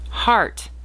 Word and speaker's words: Hart: Heart, H-A-R-T. An English name pronounced like heart, H-E-A-R-T, is typically used for boys, but we also love it for a girl's name or a middle name.